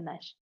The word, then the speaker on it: Nash: Así se dice mi nombre, se escribe raro, pero se dice Nash.